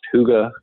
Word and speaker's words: Hygge: So we're going to go back hard to, hey, it's pronounced Huga Coworking. Okay. This is our brand, HYGGE.